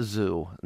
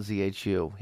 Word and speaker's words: ZHU: And before Tim Rhodes sends us a message, it's pronounced Zoo, not ZHU.